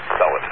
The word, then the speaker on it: Enceladus: That was Enceladus, Enceladus, as they pronounce it, Enceladus.